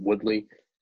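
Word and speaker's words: Woodly: I'm on Twitter at Sean Woodley. It's spelled S-H-A-U-N-W-O-O-D-L-Y.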